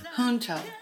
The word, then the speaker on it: junto: The Junto word is Spanish for together, and I think it's actually pronounced junto.